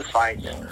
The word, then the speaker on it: FITE: He's American, and he's doing mainly the European fights. But not only on DAZN, but there's another thing called Fight TV that's spelled FITE.TV.